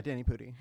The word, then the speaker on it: Danny Pudi: Yeah, Danny Pudi, yeah.